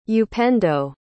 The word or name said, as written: upendo